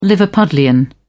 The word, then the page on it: liverpudlian: British English